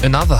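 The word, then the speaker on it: ANOTR: Und ich weiß nicht, ob ich den Namen des Artists richtig ausspreche, aber Anotra featuring 54 Ultra. – Another heißt er.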